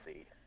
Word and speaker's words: ITZY: No, well, ITZY, instead of spelling it, because it's spelled I-T-Z-Y. Etsy. Etsy.